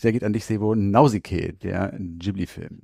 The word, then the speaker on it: Nausicaä: Übrigens, ich würde das Nausicaä aussprechen, weil im Film wird sie ja auch so genannt und der Untertitel ist Im Tal der Winde. – Na gut. – Ich sag mal Nausicaä.